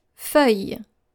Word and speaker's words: feuille: And I have many students who have struggled with this word as well when I ask them to read something, and they also tend to pronounce the double L in this word. And this is how we spell this word. F-E-U-I-L-L-E. Encore une fois, F-E-U-I-L-L-E.